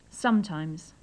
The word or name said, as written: sometimes